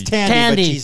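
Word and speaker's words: Thandiwe: Thandwe Newton? Is that how it's pronounced? – It's Tandy. – But she's now changed her spelling of her name. – Oh, that's why. – Still said the same way.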